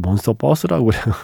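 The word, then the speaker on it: Monsterverse: 제가 자꾸 이걸 몬스터버스라고 발음을 하다가 예 이거는 그 버스 아닙니다. 예, 몬스터버스입니다. 유니버스의 그 버스.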